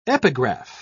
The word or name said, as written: epigraph